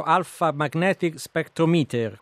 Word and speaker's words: Alpha Magnetic Spectrometer: Tra l'altro lei ha pronunciato anche molto meglio di me il nome di questo strumento straordinario, Alpha Magnetic Spectrometer.